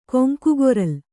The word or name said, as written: ಕೊಂಕುಗೊರಲ್